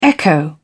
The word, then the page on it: echo: British English